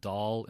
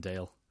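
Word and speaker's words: Dáil: and It's the little thing over the A that every time. father. That's called the father makes it from an A to from an a, uh, like a hard A to like a soft A to a long A. Right. If that's the way to think it. That's why it's fina fall and Doll instead of fina Fail and Dale.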